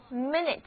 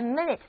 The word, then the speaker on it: minute: 分钟相对于小时来说就是一个相对比较小的计算单位，所以词根 min 的后面加上 ute 这个形容词或者名词后缀，就得到了分钟，念作 minute, minute。比如说请等一会儿，就是 wait a minute please, wait a minute please, 请等一会儿。在这里字母 I 和 U 都读作短的 I， minute, minute。但是如果我这样来念 minute, minute，哎，这个时候不但重音移到了第二个音节，而且字母 I 和 U 都不再读短的 I，而是分别读作它们各自的本音 I 和 U，于是这个单词的意思也就变了。